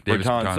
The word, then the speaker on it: Bertans: Bertans? Is that how you pronounce his name? – Yes, Bertans.